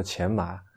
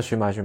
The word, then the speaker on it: 荨麻: 对草字头下面一个寻找的寻这个字读钱，它应该叫做钱麻。嗯，然后，呃，过人跟我说，最近还是已经啊，字典里面已经是这个字已经收录了寻这个读音了。嗯，因为大家念的是寻，所有人都在念寻，几乎没有人念钱，都管它叫荨麻荨麻荨麻，所以就是这是一个活生生的由于使用然后改变了这个字的一个属性的一个例子，所以我觉得我必须今天去刺那么一下。